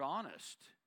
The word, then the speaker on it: honest: Well, then the word honest, the H is silent.